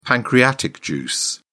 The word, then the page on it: pancreatic juice: British English